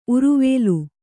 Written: ಉರುವೇಲು

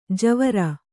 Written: ಜುವ್ವಾರೆ

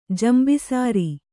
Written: ಜಂಬಿಸಾರಿ

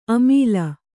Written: ಅಮಿಲ್

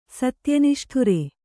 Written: ಸತ್ಯನಿಷ್ಠುರ

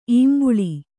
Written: ಈಂಬುಳಿ

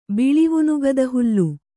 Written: ಬಿಳಿವುನುಗದಹುಲ್ಲು

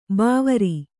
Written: ಭವಾರಿ

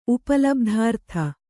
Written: ಉಪಲಬ್ಧಾರ್ಥ

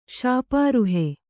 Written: ಶಾಪಾರುಹೆ

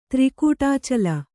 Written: ತ್ರಿಕೂಟಾಚಲ

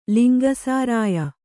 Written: ಲಿಂಗಸಾರಾಯ

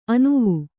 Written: ಅನುವು

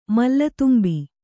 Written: ಮಲ್ಲತುಂಬಿ

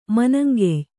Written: ಮನಂಗೆಯ್

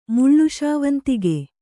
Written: ಮುಳ್ಳುಶಾವಂತಿಗೆ

